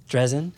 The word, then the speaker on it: Drezen: I'm here with Lilit Marcus, who's an editor for our website, and also special guests Anna Drazen and Todd Dakota Briscoe. Did I get those right? – Yeah. – Almost right? – Basically. – Close? – Yeah. Drazen. – Yeah. – Drazen. – Rhymes with present.